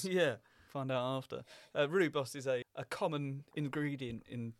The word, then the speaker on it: rooibos: Rooibos. – Rooibos. Oh, I see. Rooibos, South African name.